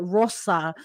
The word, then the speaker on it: Rosa: When I tell people Rosa, I shorten my name to Rosa, and people still say it incorrectly. And I remind them, it's like the Japanese, you know, the vowels, the Japanese vowels, like Rosa.